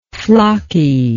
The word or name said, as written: flocky